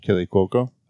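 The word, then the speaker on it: Kaley Cuoco: Kaylee Cuoco, how do you say her name? – Kaylee Cuoco.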